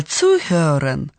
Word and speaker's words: zuhören: У глагола zuhören – слушать, приставка тоже ударная. – Zuhören. – Du musst gut zuhören. – Да, в отличие от глагола hören – слышать, глагол zuhören означает направленное внимание – слушать.